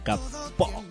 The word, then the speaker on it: Capó: Y antes en el puesto número 14, Camilo, la versión remix de Tutu, junto a Shakira y Pedro Capó. Acento en la O.